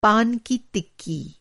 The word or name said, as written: तिक्की